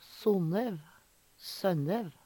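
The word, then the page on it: Sonnev, Sønnev: Numedalsmål; Veggli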